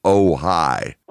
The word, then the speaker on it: Ojai: You have an album which is O-J-A-I. It's pronounced Ojai.